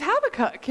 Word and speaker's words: Habakkuk: And I'm like, it's Habakkuk.